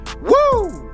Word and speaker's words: Woo: Actually, it's pronounced Woo tungsten. W O O exclamation mark, because that's the sound you make when you catch a giant bass.